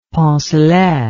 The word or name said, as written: poncelet